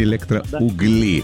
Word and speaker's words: Электроугли: Вот, электроугли и электроугли. Да. Пытались мы разобраться, если что, ударение на «у» — электроугли, а жители города электроугли как зовутся, стало быть? – По мне так электроуглята, я не знаю.